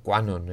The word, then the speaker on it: QAnon: pseudocientíficas como puede ser la teoría Quanon. Eh, QAnon se escribe, si lo queréis buscar.